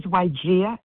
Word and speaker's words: Wajeeha: This is Wygia in Maryland. – Hey, Wygia, could you spell that for me? – W-A-J-E-E-H-A.